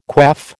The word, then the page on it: quaff: American English